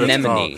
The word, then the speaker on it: anemone: No, a sea enema or some shit. I know that's not what anemone. – It's called. Thank you. – Anemone. – Anemone. I said it right.